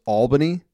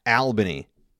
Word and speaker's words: Albany: Uh, it's pronounced Albany, not Albany. All right. I know there's only it's spelled like Al. It's pronounced all.